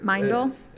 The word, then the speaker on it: Meindl: Mindel? – And then, yeah, that's pretty good. You said exactly right. Most people want to have an extra syllable. – Mindel. – Yeah, I've gotten Mendel a lot, Mindel even, but it's Mindel.